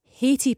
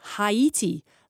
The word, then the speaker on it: Haiti: Brian in Langside, not a name mispronunciation that is irritating me, but as it's ongoing, Haiti pundits and others are saying Haiti, like Tahiti, but it should be Haiti. Come on, Scotland. This is anonymous. Yes. M-E-N-Z-I-E-S is Mingus, not Menzies.